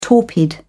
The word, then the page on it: torpid: British English